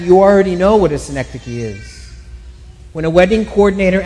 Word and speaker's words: synecdoche: And she told me, synecdoche.